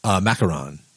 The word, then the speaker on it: macaron: Okay, so Daniel says, is it Maracon? Is that how we pronounce that? – Macaron.